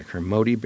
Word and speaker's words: Kermode: Yeah, Kermode bear.